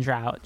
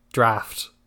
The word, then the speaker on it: draught: It's that word is pronounced draft. – Is it? – Yeah. – Wait a second. Is it really? – It really is. I'm not fucking with you. Right? I was actually kind of taken aback when you pronounced it draught during the summaries. – It's draft.